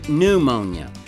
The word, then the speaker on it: pneumonia: No, it's really pneumonia. The P is silent.